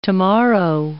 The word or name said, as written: tomorrow